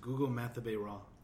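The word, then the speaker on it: Gugu Mbatha-Raw: This is literally how you pronounce it. Gugu Mathabe-Raw.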